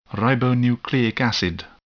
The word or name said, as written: ribonucleic acid